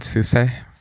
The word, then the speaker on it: SUSE: I've gone through many pronunciations of this in my life, but I think I've settled on SUSE for right now. – SUSE. – It's SUSE. German. We need a German to pronounce it for us.